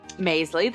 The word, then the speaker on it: Maisley: Maisley, that's right. Mm-hmm. – Like Paisley with an M.